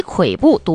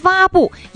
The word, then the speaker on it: 跬步: 近日有网友在社交媒体发布视频啊，说中公教育旗下中公网校一名授课老师在讲课的过程当中，把这个不羁跬步读成了不羁蛙步，引发网友关注和热议。